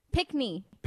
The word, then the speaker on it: pickney: Pickney. – Pick me. – No, not yeah. me. – No. – Oh. Knee. – Knee. – P-I-C-K-N-E-Y. – Pickney. – Yeah. – Mm-hmm. – Yes. Oh, a pickney.